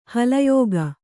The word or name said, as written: ಹಲಯೋಗ